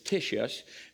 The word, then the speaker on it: Titius: Then he left there and went to the house of a man named Titius. I know that looks like it's spelled Tatius, but it's Titius, Justus, a worshiper of God, whose house was next to the synagogue.